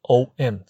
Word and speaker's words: OM: 标准的念法，在念完的时候必须要闭唇，而同学所看到这个英文字幕 RO 这两个音合起来可以做 O，闭唇音可以做 M，这么理解的话非常符合 OM 字这个字的发音，所以在两种英文的拼音的方式当中， OM 也就是 OM 这个发音是比较准确的。